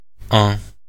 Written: un

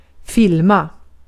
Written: filma

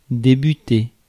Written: debut

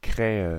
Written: crow

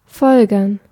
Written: deduce

